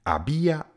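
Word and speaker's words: había: Tak my to a sloveso aber, protože končí na r, předěláme právě do té formy ia, tedy abia, abias, abia. Abiamos, abiais, abian. Pozor, píšeme z h, habia, habia, zabia, ale h nikdy nevyslovujeme.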